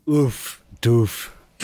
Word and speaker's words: doof: Oh, you got to pronounce that word right. It's doof. Everyone doof. In America says doof. – How do you say it? – Doof. – Doof. Doof. Doof. Oh, okay. – It's like a ooh, like oof.